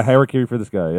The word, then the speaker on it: Harakiri for the Sky: Actually, yesterday I started listening to this band called, I might pronounce it wrong, but Harakiri for the Sky. – Yes. Yes. Yeah. – Is that how you pronounce it? – Yes. Yeah, Harakiri for the Sky.